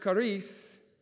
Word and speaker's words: charis: It's pronounced charis, and it means grace.